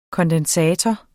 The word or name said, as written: kondensator